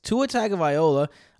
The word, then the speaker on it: Tua Tagovailoa: Tua Tagovailoa. Did I say that right? I said that right, right? – Good job saying that right.